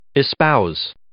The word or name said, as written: espouse